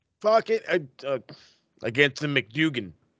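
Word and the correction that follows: McDonagh: Is that how you say it? – Oh, yeah, McDonough.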